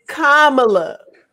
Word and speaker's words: Kamala: Now this brother was bumped up to what Kamala's detail. And everything, you know, escorting her out. – First off, can we let me clear this up right now? It's Kamala.